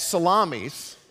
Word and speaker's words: Salamis: When they arrived at Salamis, I know you think it's not pronounced like salami, but it is. Salamis, they proclaimed the word of the God, the word of God in the Jewish synagogues.